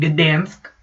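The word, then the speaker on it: Gdańsk: Gdansk, apparently, is the way you pronounce it. And the commentary was Dansk.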